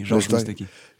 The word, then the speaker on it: Georges Moustaki: Georges Moustaki